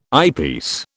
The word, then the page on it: eyepiece: British English